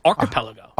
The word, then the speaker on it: archipelago: No, it's not an archie. It's archipelago. – Archipelago. – Archipelago. – He's just emphasizing the wrong syllable.